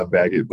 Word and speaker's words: Beckett: Yeah, Beckett. But I mean, I've been called French bread as a kind of a nickname because of how people think it's pronounced. But yeah. Beckett. So it's all good.